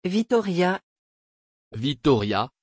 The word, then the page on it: vitória: French